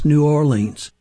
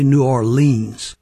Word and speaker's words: New Orleans: As you can tell, Do You Know What It Means does not rhyme with New Orleans. It rhymes with New Orleans.